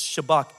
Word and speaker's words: Shabach: It, it almost sounds like the word Sabbath, the Shabbat, but it's Shabach.